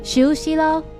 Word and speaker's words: 仇思郎: 补充一下，仇思郎的日语念作しゅうしろ，而去自首吧日语念作じしゅうしろ。